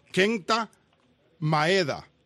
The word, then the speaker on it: Kenta Maeda: El pitcher japonés, y lo quiero pronunciar bien, porque sin querer lo he pronunciado mal, Kenta Maeda. ¿Ok? Lo pronuncié bien esta vez.